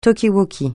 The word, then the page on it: talkie-walkie: francais